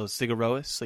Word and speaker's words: Sigur Rós: making you say the O itself, right? So Sigur Rós, like you say O Rose.